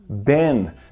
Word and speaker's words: been: Então, algo que você talvez tenha notado é que no contínuo eu sempre estou usando o verbo been, B-E-E-N, que é o particípio do verbo to be. Be, was, were, been. No inglês americano, a pronúncia é de been, been. Já no inglês britânico, você vai ouvir been, been, I have been. Então, não é errado ouvir nem been, nem been, I've been.